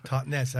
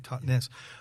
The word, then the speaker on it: Totnes: Yeah, it's Totnes, as you say it properly, Totnes.